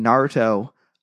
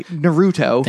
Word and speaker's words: Naruto: Also, I was curious if either of you have ever seen Naruto. – I said that wrong. – Oh, shoot. I'm sorry. Naruto.